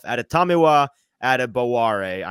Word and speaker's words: Adetomiwa Adebawore: Why don't we start with the Northwestern product himself, Adetamiwa Adebare. I'm going to say it as many times as I can because I'm very proud that I've nailed this pronunciation at this point.